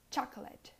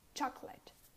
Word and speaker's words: chocolate: The next often mispronounced word is chocolate. This has two syllables. It's pronounced with two syllables at least. And you say chuck, chuck, kind of with an A, and let, let. Chocolate, chocolate, chocolate.